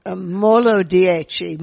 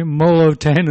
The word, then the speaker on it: Molo Dieci: And you need to be careful because Peter kept on calling it, because it's spelled out, M-O-L-O, and then the number 10. So I kept on calling it Molo 10, and I suddenly realized people had absolutely no idea what I was talking about.